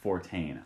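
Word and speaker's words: Fortin: By the way, listeners, it is pronounced Fortane. I looked it up in the official Blackhawks roster pronunciation guide.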